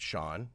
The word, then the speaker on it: Chaon: And I just wanted to remind your listeners that even though the name is pronounced Shaun, it's spelled C-H-A-O-N, which is very confusing for many on the radio.